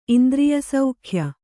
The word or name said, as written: ಇಂದ್ರಿಯಸೌಖ್ಯ